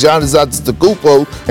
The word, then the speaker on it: Giannis Antetokounmpo: Now, yes, he went up against Giannis Antetokounmpo, and I got his name right on the first try.